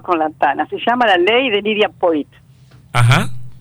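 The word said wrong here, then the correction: Poët: Lidia Poet, perdón, que lo que pasa es que tiene dos puntitos arriba, la, este, se escribe como poeta, pero sin la voz final, poeta, sin la voz final.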